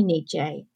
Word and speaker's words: Nietzsche: Mussolini also studied some other philosophers, including, I hope I say this right, Nietzsche. – Yeah, Americans say that one way and we say it another. I would say Nietzsche.